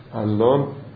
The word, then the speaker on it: علان: گاهی قد از سنخ تبدیل یک اسم به اسم عجمی، به لهجه محلی، مثل علان کلینی، علی ابن محمد کلینی با علان، علان همون علی هست که در لهجه فارسی علان تلفظ می شده.